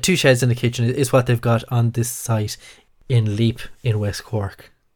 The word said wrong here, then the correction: Leap: Lep, I believe it's pronounced.